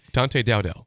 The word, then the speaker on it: Dante Dowdell: Dante Dowdell.